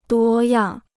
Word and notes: 多样: duō yàng

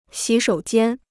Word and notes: 洗手间: xǐ shǒu jiān

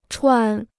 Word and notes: 喘: chuǎn

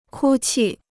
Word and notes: 哭泣: kū qì

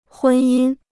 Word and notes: 婚姻: hūn yīn

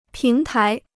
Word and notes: 平台: píng tái

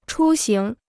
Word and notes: 出行: chū xíng

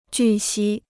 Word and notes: 据悉: jù xī

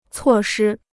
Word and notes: 措施: cuò shī

